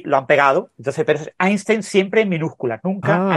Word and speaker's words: Einstein: Einstein.